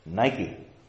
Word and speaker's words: Nike: And the Greek word for victory, used twice in this verse, is Nike. I pronounce it the contemporary way, not the Greek way. Nike, victory.